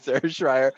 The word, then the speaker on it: Schreier: Schreier rhymes with fry. She helped me out so I can pronounce it correctly. Sarah Schreier from Minneapolis City.